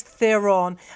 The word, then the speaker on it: Theron: It's Theron. Everyone always says Theron. Okay. – I know. – Charlize Theron.